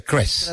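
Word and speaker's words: Kreß: El doctor Klaus Kress, que se escribe K-R-E como una B, ¿no, Juan Pablo? Pero se pronuncia Kress. – Alemana, que es como una doble S.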